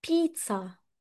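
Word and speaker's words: pizza: طيب اللي بعد كده بقى شفتها ناس كتير بتغلط فيها يا شباب، بيتزا. بيتزا هي فيها زي خلاص، هي بتتكتب بي اي دبل زي اي، بس هي بتتنطق بيتزا مش بيتزا ولا بيتزا ولا حد مثلا يقول لك بيتزا. بيتزا كأنها س كأنها اس.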